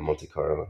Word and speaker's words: Monte Carlo: Last time you went to Monte Carlo, is that how you say it, Monte Carlo? – I went to the casino, yeah, Monte Carlo.